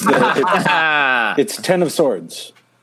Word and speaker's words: X of Swords: It's pronounced the Hickman of Swords. It's Ten of Swords.